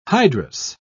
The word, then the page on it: hydrous: British English